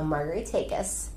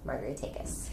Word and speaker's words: Margaritakis: Okay, so Margaritakis. Okay. Margaritakis. – Takis. – Yes. – A long A.